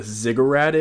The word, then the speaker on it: ziggurat: And if you guys know what a ziggurat is that how you pronounce it? – Ziggurat, yeah.